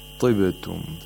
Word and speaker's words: طبتم: طبتم. The ta has tafkhim here, but it has the lowest level of tafkhim.